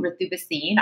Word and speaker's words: Ritu Bhasin: And so, my name is Ritu, Ritu Bhasin. I go by the Indian pronunciation.